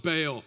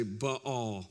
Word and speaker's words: Baal: Okay, so I've always heard my entire life that this is called Baal, and I'm going to keep saying Baal. But if you would like to have a more accurate pronunciation with the Hebrew, you could say Baal.